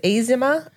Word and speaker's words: AZIMA: Is it Azima or AZIMA? It's pronounced Azima.